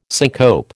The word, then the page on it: syncope: American English